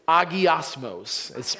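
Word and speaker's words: agiasmos (ἁγιασμός): The Greek word is agiasmos. It's spelled H-A-G-I-A-S-M-O-S. Agiasmos.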